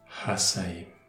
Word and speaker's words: Hasaim: Hasaim. H-A-S-A-I-M. Le H se prononce comme une expiration.